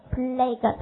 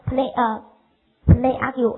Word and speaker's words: plague: plague 读成 plague 哈，听明白？读成不给我不要给我读成 play argue 哈，很恶心哈，听明白？就是 plague， OK 哈，这些单词能不能写出来？